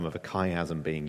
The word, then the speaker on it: chiasm: We've seen those once or twice in the Psalms and so on, named for the Greek letter chi, you know, shaped like an X that's pronounced as a hard CH. So we call this a chiasm.